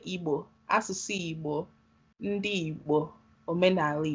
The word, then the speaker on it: Igbo: I know you posted about how the pronunciation and spelling of our culture and our people is not Igbo, I-B-O. Igbo is the way it's spelled and pronounced.